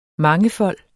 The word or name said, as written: mangefold